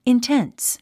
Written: intense

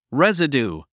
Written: residue

